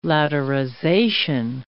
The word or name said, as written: laterization